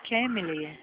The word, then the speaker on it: Kemily: Como é que é a pronúncia? – Kemily. K-E-M-L-E. – Ah, sim, Kemily.